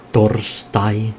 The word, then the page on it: torstai: [ˈt̪o̞rstɑi]; /ˈtors.tɑi/